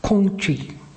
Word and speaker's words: Kongqi: First of all, Confucius' name and pronunciation was Kongqi, okay, with the family name first. In Chinese, he's often known as Kongzi or Kongfuzi.